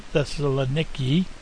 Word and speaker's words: Thessaloniki: That's the way the Greeks pronounce it, Thessaloniki.